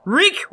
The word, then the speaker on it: Reek: Reek rhymes with freak from the United Kingdom says, best gut podcast.